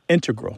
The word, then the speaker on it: integral: Both correct, just to clarify here, both correct, but the mathematicians and math-using scientists are more likely to integral with the stress on the first syllable.